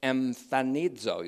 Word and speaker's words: emphanizo: It's emphanizo. You'll see it in the notes if you want the notes. E M P H A N I Z long O. Emphanizo.